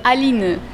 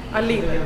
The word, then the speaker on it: Aline: Aline. Aline. It's pretty much French.